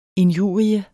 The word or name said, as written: INJURIERNES